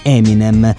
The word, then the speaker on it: Eminem: Nel 1996 ha pubblicato da solista il disco Infinite per la prima volta con lo pseudonimo Eminem, che è in pratica la pronuncia del suo precedente nome d'arte, appunto M&M.